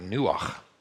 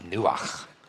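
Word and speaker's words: nuach (נוח): And then the second word, the word that we've translated here for God resting, is actually the word nuach. Rhymes with ruach. Yeah. Say it, it's fun, nuach. You'll know you say it right, as we always say, if you spit on the person in front of you, right?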